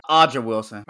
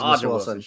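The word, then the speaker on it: A'ja Wilson: I don't know how to pronounce her name. She's a baller. – Aja Wilson. – Aja. Yeah. My apologies, yeah, Aja Wilson.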